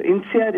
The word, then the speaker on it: INSEAD: INSEAD. – Okay. Well. First, tell us a little bit about that, and then we'll get into the book. – Right. So INSEAD is we're a global business school,